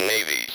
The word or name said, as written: navy's